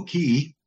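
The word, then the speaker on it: Cay: While we were there, we also had a chance to visit Elbow Key. That C-A-K is pronounced Key in the Bahamas.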